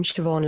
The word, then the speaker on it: Siobhan: You can look up my name, Siobhan Lyon. So my first name is Irish. So it's S-I-O-B-H-A-N.